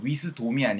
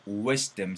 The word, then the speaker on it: wisdom: OM으로 끝나도 약간 M 사운드가 만들어지면 좋아요. wisdom이 아니고 wisdom 사운드가 만들어지면 좋고요. custom보다는 custom 사운드가 만들어지면 좋아요. 그래서 요것도 발음 한번 들려드릴까요? custom이 아니고 custom, wisdom 이런 식으로 사운드가 나야 돼.